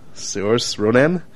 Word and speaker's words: Saoirse Ronan: Oh, this is Saoirse Ronan? – Mm-hmm.